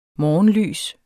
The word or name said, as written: morgenlys